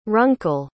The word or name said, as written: runkle